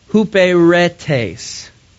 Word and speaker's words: huperetes: Servant is from the Greek word huperetes. I said that right the first time.